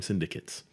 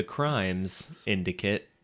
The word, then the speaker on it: syndicate: I feel like you put the emphasis on the wrong syllables there. What's that? – On what? – You said the crimes indicate. – No, I did not. – Syndicate. – I know, but he said crimes syndicate.